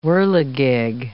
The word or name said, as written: whirligig